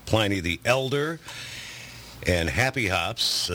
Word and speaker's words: Pliny: Yeah, the correct pronunciation for the philosopher Pliny the Elder is Pliny, yes.